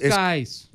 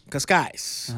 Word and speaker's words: Cascais: en Cascais, Portugal, espero estarlo pronunciando bien. Invitados. – Cascais. – Cascais.